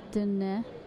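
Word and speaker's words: Diné: Now, am I saying it right? Because I think I just naturally have gravitated to saying Dine, but there again, Dine. There's a little nuance in the name. – Yeah, it's Dine.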